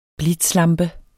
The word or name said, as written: blitzlampe